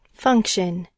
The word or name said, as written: function